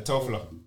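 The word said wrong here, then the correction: Telfar: How do you pronounce it? It's Telfer.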